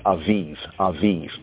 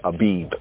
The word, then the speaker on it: Abib: Or some, the Khazarim pronounce it as Aviv, but more properly is Abib, like Addis Abeba. It's from Abebe, not Aveve.